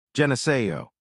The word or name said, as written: Geneseo